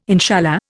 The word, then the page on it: inshallah: Indian